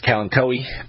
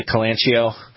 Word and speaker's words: Kalanchoe: Depending upon how you pronounce it, I've always pronounced it as Kalanchoe, but there's the Calanchio.